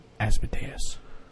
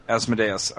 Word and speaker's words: Asmodeus: But what's the right pronunciation? – Asmodeus. – Asmodeus, all right.